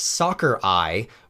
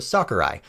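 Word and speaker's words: Sakurai: Fun fact four, there's a trick to properly pronouncing the name of Smash Kirby's creator. Simply pronounce it as Soccer Eye without the pause for the dash, so Soccer Eye.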